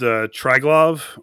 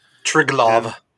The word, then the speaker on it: Tryglav: It's called Triglav, or Triglav, I'm not sure exactly how to pronounce it, but it's T-R-Y-G-L-A-V. – Triglav.